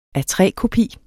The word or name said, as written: A3-kopi